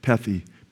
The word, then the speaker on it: pethi: Pethi. And the transliteration in the Greek would be P-E-T-H-I, but pronounced pethi in Hebrew.